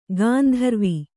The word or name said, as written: ಗಾಂಧರ್ವ